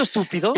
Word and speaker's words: estúpido: ¿Tiene acento estúpido? – Igualmente, la antepenúltima sílaba, por lo tanto, estúpido es una palabra esdrújula.